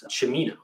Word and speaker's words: Cimino: I should note though that my last name is pronounced Cimino, like Al Pacino.